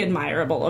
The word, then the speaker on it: admirable: At the same time, it's just also, like, really admirable of him of, like... Wait, yep, that's a word. – Admirable. – You know what I meant. – Put the emphasis words. On the wrong syllable.